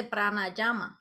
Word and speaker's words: pranayama: También estaba viendo eh, que si nos puedes explicar un poco más de lo que son las técnicas de pranayama. No sé si lo dije bien. Sí, pues mira, el pranayama es el arte de la respiración.